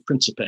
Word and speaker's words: Principe: People pronounce his name various ways. – Right. – The dominant pronunciation is Principe.